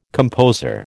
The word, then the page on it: composer: American English